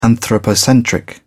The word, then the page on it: anthropocentric: British English